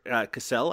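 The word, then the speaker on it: Cassel: Seymour Cassell, I think it's pronounced. – Is it Cassell? – I think so. Yes.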